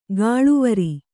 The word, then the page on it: ಗಾಳುವರಿ: gāḷuvari